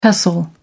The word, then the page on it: pestle: American English